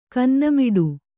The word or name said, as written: ಕನ್ನೆಮಾಡ